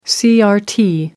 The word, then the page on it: crt: American English